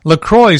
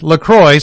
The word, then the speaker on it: LaCroix: Officially, it's pronounced as LaCroix, so it rhymes with enjoy, not La Croix or La Croix, like the French would probably say it.